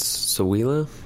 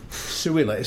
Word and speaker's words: Ciulla: I believe it's Suila, but I'm going to butcher that too. – Suila. C-I-U-L-L-A.